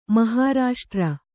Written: ಮಹಾರಾಷ್ಟ್ರ

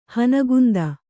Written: ಹನಗುಂದ

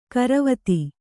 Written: ಗೊರವತಿ

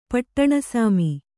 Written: ಪಟ್ಟಣಸಾಮಿ

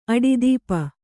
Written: ಅಡಿದೀಪ

